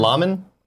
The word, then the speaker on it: Luhrmann: Was it Baz Luhrmann? – Laman? Baz? – Oh, it's Luhrmann. I know that's how you say his name, but I don't know if that's him as the director.